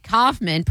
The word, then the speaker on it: Kaufman: And Kaufman, pronounced Kaufman, rhyming with cough plus man.